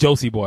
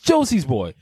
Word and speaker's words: Josie's Boy: It's like people speak to me and they completely take the S right out the middle. It's just Josie Boy. I'm like, no, it's Josie's Boy.